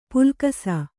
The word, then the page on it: ಪುಲ್ಕಸ: pulkasa